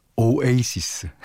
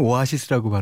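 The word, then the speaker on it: Oasis: 오에이시스. 아, 우리나라에서는 오아시스라고 발음하지만 그 뭐 미국에서나 영국에서는 다 오에이시스라고 발음하더라고요.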